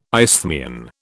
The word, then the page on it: isthmian: British English